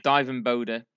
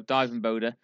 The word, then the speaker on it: Dijvenbode: I think Dirk van Dijvenbode, I think it's pronounced something along those lines. – Who's a Dweeb? Dijvenbode. – Dijvenbode. There you go. Dijvenbode.